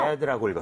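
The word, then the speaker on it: read: 처음에 read라고 했다가 뭐라 그랬죠? – red겠네요. – red라고 읽었어요.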